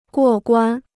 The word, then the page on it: 过关: guò guān